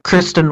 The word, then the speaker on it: Kristen: Kristen Wiig.